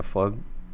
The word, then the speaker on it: Flesner: How'd you guys get linked up with the Flesner? Is that, am I pronouncing it right? – The Flinzer. Yeah.